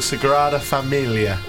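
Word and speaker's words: Sagrada Família: I went to a big church called the Sagrada Familia. – Oh, nice pronunciation.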